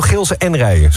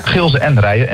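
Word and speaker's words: Gilze en Rijen: En het is echt officieel Gilsen en Rijen. Zo moeten we het uitspreken. – Ja, Gilsen en Rijen.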